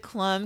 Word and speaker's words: Klum: And I'm so glad you pronounce it Klum, because Klum kind of rhymes with chum.